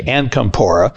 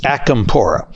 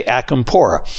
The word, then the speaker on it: Acampora: And sometimes I say Ancampora versus Acampora. And my good friend Ralph lets me get away with that, but I will try to make sure next time I have Ralph on the show, I say Acampora.